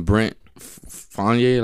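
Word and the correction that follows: Brent Faiyaz: Brent Fayez.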